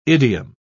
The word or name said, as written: idiom